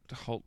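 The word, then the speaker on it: Hoult: Is it Nicholas Holt or Hout? Holt. I don't know how you pronounce Holt. – It. – Nicholas Holt. – Holt, that's what I thought.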